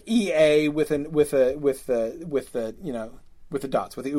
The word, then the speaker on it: Eä: So that's why, you know, so when Iluvatar says Ea, it's two syllables. That Ea, that one word that is Ea is two syllables, and that's indicated by the umlaut, the two.